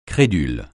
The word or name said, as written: crédule